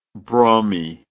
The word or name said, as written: brummy